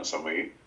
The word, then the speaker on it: Osamoyi: So I introduced myself as Osaruman Osamoyi. – Okay. – Because of how it's spelled, it's the most straightforward way to pronounce a name.